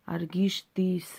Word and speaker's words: Аргишты: Ереван старше Рима на 30 лет – и в клинописи царя Аргишты написано «Я, Аргишты, сын, сын Минуа, построил э, город сей, неправильно сказал ударение, Аргишты, сын Минуа, построил сей город во славу моего рода и э, моего имени.